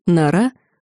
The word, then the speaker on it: Нора: Светлана Норкина, более известная в монастыре как Нора, с ударением на втором слоге, убрала ладони с клавиш ноутбука, введя последнюю строку кодовой последовательности.